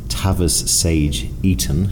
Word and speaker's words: Tavis Sage Eaton: His name is Tavis Sage Eaton.